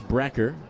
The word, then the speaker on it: Broecker: Brecker is spelled B-R-O-E-C-K-E-R. He'll pronounce Brecker.